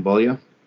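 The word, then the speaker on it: Beaulieu: I can never pronounce his last name for whatever reasons. – Bulia? – Yes, that one.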